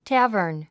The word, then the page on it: tavern: tǽvə r n; テェヤバン